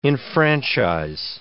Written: enfranchise

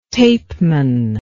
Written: tapeman

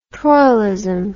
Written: troilism

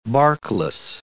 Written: barkless

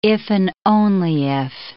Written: iff

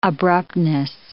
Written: abruptness

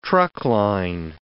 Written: truckline

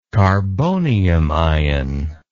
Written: carbonium ion

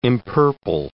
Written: empurple

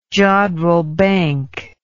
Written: jodrell bank

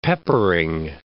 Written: peppering

